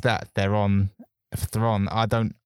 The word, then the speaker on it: Theron: On a side note, Charlize Theron, I heard that on a radio show that it's not pronounced Theron, it's Thron.